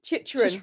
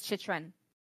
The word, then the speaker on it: Chychrun: Shishrun. Oh my god. Jacob Shishrun. – Chychrun. – Yes, Chychrun? You're right. It is Chychrun.